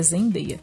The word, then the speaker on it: Zendaya: então, a Zendaya, assim, o, o, fandom de Zendaya que foi. Zendeia, na verdade, eu tô falando errado, é Zendeia.